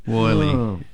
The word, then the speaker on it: Wylie: It's spelt W-Y-L-I-E. – Yeah. – So Wiley. – Wiley, but...